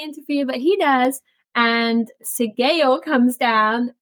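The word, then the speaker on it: Sgaeyl: I'm going to pronounce their names very wrong, but Sayi. – Sigail. – Sigail.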